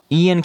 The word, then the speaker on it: Iain: Ian can be spelled I-A-N or I-A-I-N. is a name of Scottish Gaelic origin.